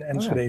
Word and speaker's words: Enschede: And Enschede is actually pronounced Enschede. But that's obvious. If you can speak Spanish, you can also say Enschede.